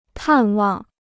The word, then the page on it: 探望: tàn wàng